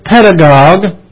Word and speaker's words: pedagogue: Now, the Greek word for tutor is pedagogos, P-A-I-D-A-G-O-G-O-S in the Greek. We pronounce it pedagogue, and pedagogue in English is spelled P-E-D-A-G-O-G-U-E. Again, pedagogos is P-A-I-D-A-G-O-G-O-S. That is the Greek word for tutor. And pedagogue, that comes into the English as P-E-D-A-G-O-G-U-E. The U-E is silent. We pronounce it pedagogue.